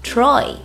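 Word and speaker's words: Troye: 要注意 Troy 中的 E 不发音读作 Troy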